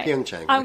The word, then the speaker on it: Pyeongchang: Pyeongchang.